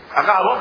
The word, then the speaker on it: Agabo: ¿Así se pronuncia Agabo? ¿O tiene acento? No puede ser Agabo, ¿o sí? Agabo, muy bien, Agabo.